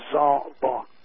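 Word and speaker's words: zabak (זבח): It means altar in English, but derives from the Hebrew word zabak, pronounced zabak.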